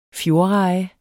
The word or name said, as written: fjordreje